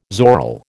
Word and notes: zoril: American English

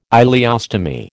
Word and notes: ileostomy: American English

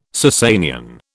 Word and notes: Sassanian: British English

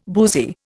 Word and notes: boozy: Indian

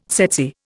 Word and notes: tsetse: Indian

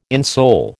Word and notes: insole: American English

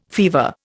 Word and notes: fever: Indian

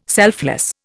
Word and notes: selfless: Indian

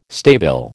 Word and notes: stabile: American English

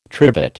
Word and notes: trivet: American English

